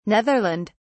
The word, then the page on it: Netherland: British English